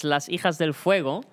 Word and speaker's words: Las Hijas del Fuego: om je uitspraak te verbeteren. Het is Las Hijas del Fuego.